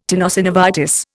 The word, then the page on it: tenosynovitis: Indian